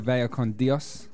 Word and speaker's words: Vaya con Dios: Okay, so I've chosen a track called Vaya con Dios. I think that's quite a good pronunciation for me. – Yeah, I think you nailed that.